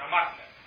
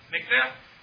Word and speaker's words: Mikneh: Furthermore, perhaps one of, maybe not the most famous expositor of this position is the Sefer HaMatneh, which is probably more correctly pronounced Mikneh, but then they won't know what you're talking about. It's from a Pesach, it should be Mikneh.